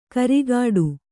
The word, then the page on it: ಕರಿಗಾಡು: karigāḍu